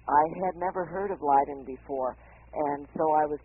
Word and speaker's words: Leiden: Did I say that correctly? – Leiden is the pronunciation.